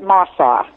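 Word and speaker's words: Mosuo: is that how you pronounce it? – I've been told they pronounce it Masaw. I mean, that's the way it sounded to me when I was there. – Oh, Masaw, people of Masaw. – Like that, yeah, Masaw.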